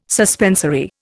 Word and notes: suspensory: Indian

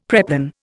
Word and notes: preplan: Indian

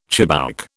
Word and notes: chibouk: British English

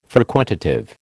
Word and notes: frequentative: American English

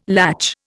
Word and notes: latch: Indian

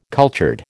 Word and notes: cultured: American English

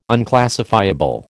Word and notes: unclassifiable: American English

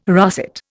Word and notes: russet: Indian

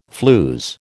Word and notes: flews: American English

